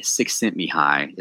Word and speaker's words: Csikszentmihalyi: And this is from Mihai Csikszentmihalyi, is how you say that dude's name.